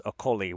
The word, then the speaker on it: Okoli: I'm learning the correct pronunciation. It's Okoli, which is just devastating as someone who is enjoying making okoli-dokoli jokes.